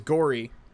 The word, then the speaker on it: Gowrie: So one of our other main characters, Gowrie, as he's pronounced, at least in the English version, is played by Eric Stewart, who's best known for the voice of James and Brock in Pokemon.